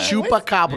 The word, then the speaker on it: Chupacabra: And there's see, obviously he's not like, even saying it right. – Yeah. Chupacabra.